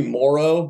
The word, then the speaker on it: Moreau: Am I saying it right, Moreau? – Close enough. It's technically Moreau, but okay.